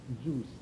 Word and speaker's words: Zeus: 发音不正确，你不要想说喝的那个酒，然后是啊，Zeus。